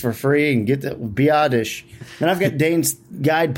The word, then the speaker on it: Biadasz: No, I think it's it's Biadasz. I mean, I just, but, hey, one of my favorite things, seriously, out of in Dane's Beast is the pronunciations. He has them for almost everybody. And yeah, he has it as B-I-Dish. B-I-Dish.